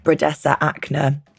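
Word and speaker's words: Brodesser-Akner: It's based on the best-selling novel by Taffy, excuse my pronunciation, Bredesa Akner.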